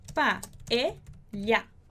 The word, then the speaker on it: paella: Então, a gente vai pronunciar como um LH de olho. Então, é ly, ly. Como é que ficaria essa palavra? Pa-e-lha. Pa-e-lha.